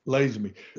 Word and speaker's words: Lazenby: Mr. Lazenby, is that how you pronounce that? Lazenby. Jack Kent Cook told me once I didn't know how to pronounce my own name.